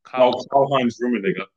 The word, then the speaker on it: Karl-Heinz Rummenigge: Karl-Heinz Rummenigge.